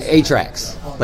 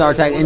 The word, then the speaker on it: Artax: Atrax, like an 8-track. – Yeah. – But no, I think it's Artax.